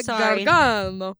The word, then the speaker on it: Gargano: And I think you said it wrong. It's Sarah Gargano.